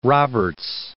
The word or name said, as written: Roberts